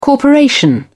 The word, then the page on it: corporation: British English